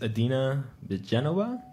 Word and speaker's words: Adina Bedenova: All right, so tonight's guest is Adina Bedenova.